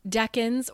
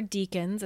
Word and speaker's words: decan: So 0 to 9, 10 to 19, 20 to 29, and those are called decans or deacons. It's pronounced both ways depending on who you talk to.